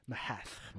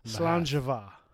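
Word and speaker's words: Sláinte mhaith: This one is S-A-L-I-N-T-E, like slancha, right? We all know that one. But then the second word is M-H-A-T-H. M-H-A-T-H. Mahath, right? – Mahath. – But it's pronounced slanjava. – Slanjava. – Oh, yeah. – So apparently M-H-A-T-H is pronounced va.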